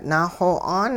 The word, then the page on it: naaxo'aan: hupa